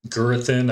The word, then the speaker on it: Gurathin: Gurathin, I hope I'm pronouncing that one correctly.